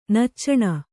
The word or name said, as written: ನಚ್ಚಣ